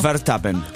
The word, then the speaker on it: Verstappen: ¿Cómo es Verstappen? Pronúnciamelo. – Verstappen, porque es holandés.